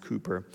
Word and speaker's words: Cowper: If you ever see it in print, it'll look like William Cowper, C-O-W-P-E-R, but it's actually pronounced Cooper.